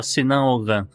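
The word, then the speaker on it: Sinan Oğan: O Sinan Oan, ou é Ogan, é, tem hora que pronuncia o G, tem hora que não pronuncia. Não, o G, não, o G contínuo não pronuncia. – Ele, ah, ele é prolonga a vogal anterior. – Ah, tá. Então é só Sinan Ogan.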